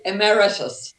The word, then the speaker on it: emeritus: Is that the right way to pronounce that, emeritus? – Well, emeritus.